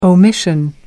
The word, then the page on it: omission: American English